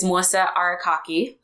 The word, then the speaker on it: Melissa Arakaki: And your name is Melissa Arakaki.